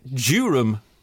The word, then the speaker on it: Durham: I refer to a specific episode where actress Bea Arthur, her character, Dorothy Zbornak, was talking about the film Bull Durham, as in County Durham, in this country. And she pronounces it Durham, which I thought was beautiful.